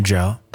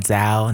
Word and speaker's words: Zhou: Joe. – Z-H-O-U, pronounced Joe. – Not Zao, not Zoo.